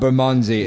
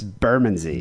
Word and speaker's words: Bermondsey: It's not Bermondsey, it's Bermondsey. – Bermondsey. Okay. – Bermondsey. Mm. I've lived here all my life. That's how you say it.